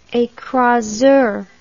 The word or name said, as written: ecraseur